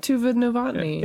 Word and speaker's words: Tuva Novotny: Tuva Novotny, I think? – I said Tuva. – Yeah, you said it right. – Tuva Novotny.